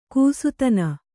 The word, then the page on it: ಗೋಸ್ತನಿ: gōstani